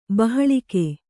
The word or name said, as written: ಬಾಹ್ಲೀಕ